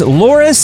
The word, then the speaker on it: Loris: Shout out goes to one of our network affiliates, Loris, South Carolina. Am I saying that right, T Bone? Yes, you are.